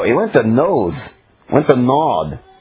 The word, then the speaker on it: Nod: You're used to reading the land of Nod. That's the land of Noth, if we pronounced it in Hebrew, same word as roaming every time.